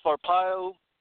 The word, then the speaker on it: Arpaio: Um, Sheriff Arpaio, definitely. Arpaio, thank you for pronouncing that for me.